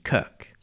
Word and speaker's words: kirk: The Scots, especially in the far north of the country, refer to it as the kirk with a hard C.